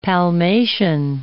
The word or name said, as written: palmation